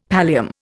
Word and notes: pallium: Indian

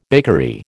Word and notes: bakery: American English

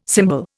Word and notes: cymbal: Indian

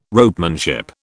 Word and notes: ropemanship: British English

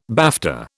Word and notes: BAFTA: British English

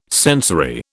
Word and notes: sensory: British English

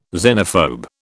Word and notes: xenophobe: British English

